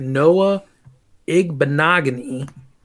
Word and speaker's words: Noah Igbinoghene: So moving into pick 30, Miami takes defensive back Noah Igbinoghene.